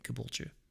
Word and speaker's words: Caboolture: It's C-A-B-O-O-L-T-U-R-E, Caboolture? – Okay. – And that's right.